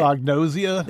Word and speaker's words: anosognosia: I don't know how to say it. – Anosognosia? – There you go.